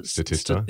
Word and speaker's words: Statista: Statista, is that how you pronounce it? – Statista.